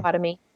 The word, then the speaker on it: Potawatomi: You're part of the Potawatomi tribe. Am I saying that right, Caitlin? Please tell me I am. – Potawatomi. – Potawatomi.